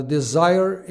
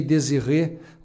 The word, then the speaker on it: Desire: Desire, eu pronunciei Désiré, ou seja, viajei para outro lado completamente diferente do título original do disco.